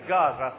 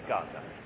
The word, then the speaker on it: Gaza: And because he said Gaza, I thought, that's how we pronounce it. Okay, Gaza.